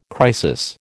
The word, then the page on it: crisis: American English